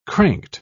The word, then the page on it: cranked: British English